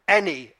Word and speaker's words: Enneagram: I've started reading it, and it's called, rather complicatedly, ENNEAGRAAM. And it's actually pronounced Enneagram.